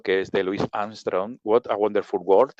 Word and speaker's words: What a Wonderful World: Sí, sí, está bien pronunciado, What a Wonderful World.